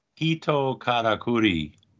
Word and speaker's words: Hito Katakuri: Hito Katakuri. Hito means Katakuri. Man, like person.